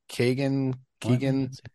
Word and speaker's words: Keoghan: Is that how you pronounce his last name? K-E-O-G-H-A-N? – Yeah. – Keegan? – Yeah, Barry Keegan, yeah.